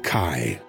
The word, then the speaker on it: Chi: It might look like an X, but you'll learn the hard way that it's pronounced Chi.